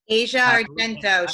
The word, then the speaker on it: Asia Argento: Asia Argento.